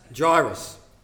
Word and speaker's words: Jairus: Jairus, sorry. How do I say it again? Jairus.